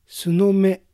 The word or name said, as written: Sunome